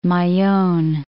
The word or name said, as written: Mayon